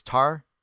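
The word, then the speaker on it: tartaroō: It's Tartarao. Tartarao. It's a form of the word Tartarus, and or not tartar sauce, by the way.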